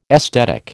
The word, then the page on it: esthetic: American English